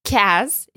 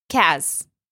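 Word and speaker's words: kaz (کز): In this case, kaz is used to have actually just 1 syllable, which fits in with the poem here, kaz.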